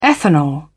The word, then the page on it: ethanol: British English